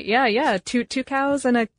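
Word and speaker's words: Tucows: Two cows.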